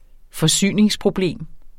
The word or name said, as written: forsyningsproblem